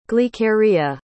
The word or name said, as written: glykeria